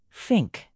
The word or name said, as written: fink